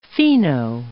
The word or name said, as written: fino